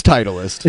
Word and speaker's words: Titleist: It's Titleist. – Is it pronounced Titleist? – Yeah. – I thought it was Titleist.